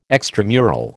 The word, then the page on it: extramural: American English